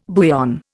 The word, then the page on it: bouillon: Indian